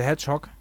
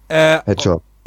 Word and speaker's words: Hedgehog: Wie spricht man das aus? – Sonic the Hedgehog. – Hedgehog, genau, so rum.